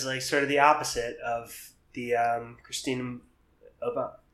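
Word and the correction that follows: Mboma: I'm saying this as a joke, which is bad because I'm not sure how to pronounce it. Mbama. – I believe. Mbama. – Sorry. Christina Mbama.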